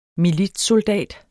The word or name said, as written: militssoldat